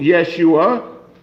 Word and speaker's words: Yeshua: So use the name that has the authority and either find out how to say it properly in Hebrew, Yeshua, which is his name.